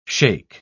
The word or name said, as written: shake